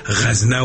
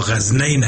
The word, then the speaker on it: غزنه: به این خاطر است که غزنی نامش تا به حال مانده و در وقتای پیش غزنه و غزنین به هر دو شکل تلفظ می شد، بعضی ها غزنین هم تلفظ می کرده، ما این درست نیست.